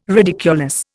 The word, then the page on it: ridiculous: Indian